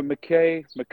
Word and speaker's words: McKay: Am I saying it wrong when I say McKay? Do you have a preferred pronunciation for that? – You know, McKay. It's Scottish, funnily enough. – Okay. – So it comes all the way from the Highlands. So it's McKay.